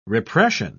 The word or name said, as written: repression